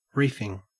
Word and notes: reefing: Australian English

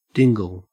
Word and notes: dingle: Australian English

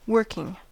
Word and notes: working: American English

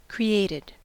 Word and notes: created: American English

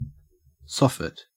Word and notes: soffit: Australian English

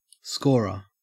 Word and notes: scorer: Australian English

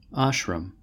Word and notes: ashram: American English

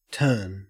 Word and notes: turn: Australian English

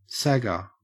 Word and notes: sagger: Australian English

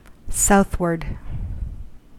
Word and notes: southward: American English

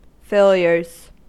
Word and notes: failures: American English

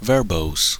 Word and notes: verbose: American English

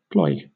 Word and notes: ploy: British English

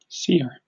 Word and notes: seer: British English

